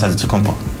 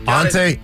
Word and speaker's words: Antetokounmpo: Whatever you gotta just say, you know, it's Giannis Antetokounmpo. – Antetokounmpo. – Antetokounmpo. – Boom. – Ante. So it's like an ant. Ante. – Antetokounmpo. – Tokounmpo.